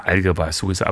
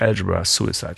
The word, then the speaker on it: Algebra Suicide: Ich sage mal Algebra Suicide, aber natürlich Algebra Suicide, stimmt. Also vollkommen richtig ausgesprochen.